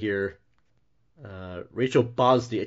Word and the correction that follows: Brosnahan: I can't read. Brosnahan. That's not right. Am I saying that right here? Brosnahan.